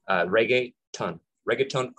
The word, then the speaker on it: reggaeton: Reggaeton. Think of reggae, right? Reggaeton. So reggae, the music is with T-O-N,